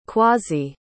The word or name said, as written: kwasi